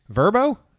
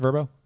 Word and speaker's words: Vrbo: We'll be using Vrbo Is that Vrbo? – Is it pronounced Vrbo? – Yes.